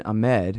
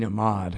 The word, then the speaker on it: Ahmad: I know a Palestinian libertarian, Dean Ahmed, who I had to interview him more often. He's really good on all this stuff as well. It's Dean Ahmad, and I always mispronounce it.